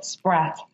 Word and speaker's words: Spratt: I don't want to Oh, Spratt.